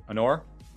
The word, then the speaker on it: Anor: Anor.